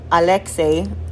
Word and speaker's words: Alexei: Actually, it's Alexei, but a lot of people can't, most people cannot say that. And it's spelled A-L-E-X-E-I, which obviously, for understandable reasons, throws people off.